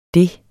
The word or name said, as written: det